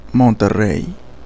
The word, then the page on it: Monterrey: [monteˈrei]; Spaingie